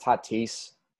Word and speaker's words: Tatis: First of all, Tradon, it's pronounced Tatis. – Not titties.